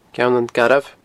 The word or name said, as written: Ceann an Garbh